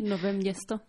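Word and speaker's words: Nové Město: Par contre, dans le quartier, alors je ne sais pas si je prononce bien, vous me corrigerez, Nové Mesto, je ne sais pas si c'est comme ça qu'on dit. – Nové Mesto. – Ah voilà, il nous faut l'accent tchèque.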